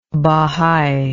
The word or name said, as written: bohai